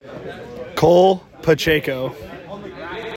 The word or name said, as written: Cole Pacheco